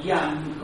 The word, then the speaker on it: Jung: Jung, o Yang, como muchos lo pronuncian, fue un psicoanalista eh, que siguió con los estudios de Freud.